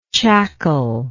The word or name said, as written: chackle